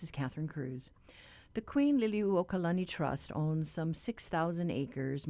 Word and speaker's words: Liliuokalani: You were on point with the pronunciation there for the birthday girl, Queen Liliuokalani. Yeah,